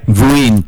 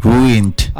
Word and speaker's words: ruined: Ya, yeah, itu bacanya ruined. – Ruined. – Ya, yeah, ruined. Ada D-nya dikit.